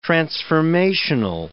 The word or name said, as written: transformational